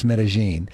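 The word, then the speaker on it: Medellín: And Medellin, it looks like it would be pronounced Medellin, but it's pronounced Medellin.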